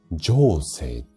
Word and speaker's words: 上星: じゃあ、どこ押せばいいのっていうと、これ、えっ、ー、と、壺の名前で言うと、情勢っ上の星、えっ、ー、と、上下の上に星で上星って読むんですが、ここのツボを押すと鼻の通りが良くなります。